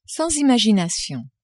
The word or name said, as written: sans imagination